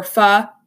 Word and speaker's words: pho: I mean, they have pho or pho if you're you know actually know how to pronounce it.